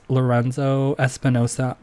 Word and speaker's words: Lorenzo Espinosa: It's Lorenzo Espinosa underscore.